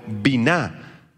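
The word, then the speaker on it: binah (בינה): Esta palabra comprender proviene del hebreo binah, con B grande y acento en la A.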